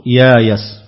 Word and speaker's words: يايس: أي قرأ البزي بخلف عنه بالقلب والإبدال، أي جعل الهمز مكان الياء والياء مكان الهمز مع بقاء هيئة الكلمة ووزنها، ثم أبدل الهمزة ألفا فيصير النطق يايس واستايس.